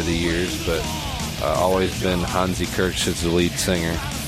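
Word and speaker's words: Hansi Kürsch: Yeah, that's right, Hansi Kirsch is how you pronounce it, at least how I pronounce it.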